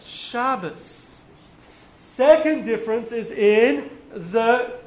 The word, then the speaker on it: Shabbos: Ashkenazic Jews will put the emphasis on the beginning of the word. Sephardic Jews put the emphasis on the end of the word. So for Ashkenazim, it's Shabbos.